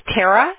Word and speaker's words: Tara: my name again is Tara, Tara. – T-A-R-A.